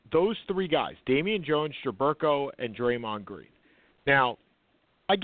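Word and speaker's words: Jerebko: Jaberko.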